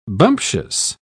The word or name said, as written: bumptious